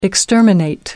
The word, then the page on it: exterminate: American English